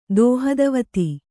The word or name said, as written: ದೋಹದವತಿ